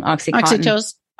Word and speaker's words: oxytocin: We have that, what's it, the oxy, I always say it wrong, oxytocin.